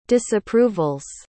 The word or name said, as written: disapprovals